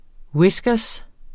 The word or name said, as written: whiskers